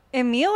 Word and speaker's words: Emill: Napoleon Emil or Emil? – You said it right the first Emil.